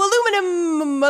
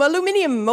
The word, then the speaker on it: aluminium: Something that in England is pronounced a little differently. – Hi ho, aluminum, aluminium, away.